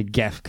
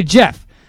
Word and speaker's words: Gef: Now, okay, so according to Jeff, I'm going to say Jeff. Like, he wouldn't say Geff because that's how it's pronounced, but I'll say Jeff.